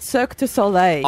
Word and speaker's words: Cirque du Soleil: It's Cirque du Soleil.